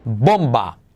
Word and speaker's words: bomb: Bomba. ¿Cómo se dice? Omitimos la B.